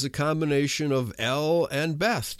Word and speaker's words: Beth: In English, usually spelled Beth, B-E-T-H, pronounced Beth.